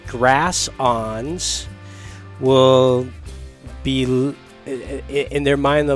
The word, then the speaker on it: awns: They've never even heard the term grass ons, and nope. – I'm pronouncing that ons, and it's spelled A-W-N-S.